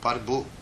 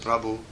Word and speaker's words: Prabhu: In Nepalis they say Parbu, Parbu. Instead of Prabhu, they say Parbu.